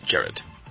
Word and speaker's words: Gerard: Well, it's pronounced Jared.